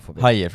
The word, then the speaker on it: Hajer: Hajer, förlåt. Ja. Är, det, är det så man ska uttala det? Ja, men det, är, det är hajer.